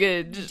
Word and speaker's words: Googe: It's probably like Debbie Googe. – Wow. – Debbie Googe. That doesn't sound any better, does it? – Yes, it does. – Debbie Googe. – Debbie. – Rhymes with Scrooge.